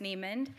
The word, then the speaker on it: Neimand: All right, well, I'm Annie Neimand. It's pronounced Neimand.